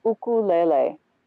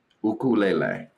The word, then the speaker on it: ukulele: So the Hawaiian syllabication is ukulele. – Ukulele. Okay. – Ukulele, because Hawaiian, you know, has beautiful syllables that repeat. Right. – And not diphthong vowels. – Uku lele. So lele is twice and u is twice. Ukulele.